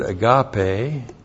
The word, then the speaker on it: agape: And that's the Greek word agape. A-G-A-P, long E.